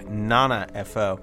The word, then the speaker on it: 7FO: The group name or the project name is spelled 7 F.O., but that is apparently pronounced Nana F.O.